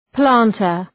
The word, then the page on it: planter: ‘plæntər